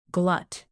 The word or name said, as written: glut